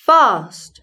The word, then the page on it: fast: British English